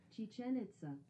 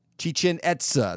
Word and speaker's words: Chichen Itza: Chichen Itza. – Chichen Itza.